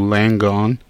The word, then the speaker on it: Langone: And Michael, am I saying that right, NYU Langone? – That's correct, NYU okay.